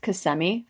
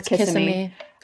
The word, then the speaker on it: Kissimmee: Also, did you know that it's pronounced Kissimmee, Florida? I always said it was Kissimmee.